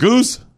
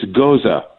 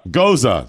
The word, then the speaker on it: Gose: Is it Goose? – It's Goza. – Goza.